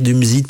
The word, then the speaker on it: Dumuzi: Et puis il y avait aussi le dieu sumérien de la végétation, c'est-à-dire euh, du Musi hein, ou Tamzu, on peut le prononcer de plusieurs manières, Dumuzi, Tamzu, il y, il y a plusieurs versions de ce dieu sumérien qui était donc adoré comme arbre de vie.